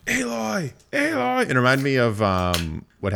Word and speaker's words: Aloy: It's pronounced Aloy, not Alloy.